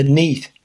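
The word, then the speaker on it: Neith: Now, the Neath, that's a funny name.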